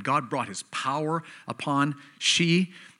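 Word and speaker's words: Hsi: This is from a pastor in China, Pastor She, which is spelled H-S-I, I guess. So it's Pastor She.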